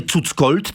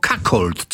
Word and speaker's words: cuckold: Pisze się to przez dwa razy C, cuckold, ale czyta się przez K, prawda? Bo nie cuckold, tylko cuckold. Co to jest?